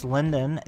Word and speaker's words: Lyndon: And you can find me under the name Chris Lyndon. That's spelled out LYNDON, as in Lyndon Johnson.